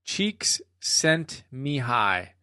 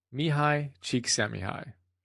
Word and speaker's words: Csikszentmihalyi: So let's start by learning how to pronounce his name. According to Martin Seligman, the author of Authentic Happiness, and you can see the notes on that, it's Mihai is his first name, Csikszentmihalyi. Mihai Csikszentmihalyi.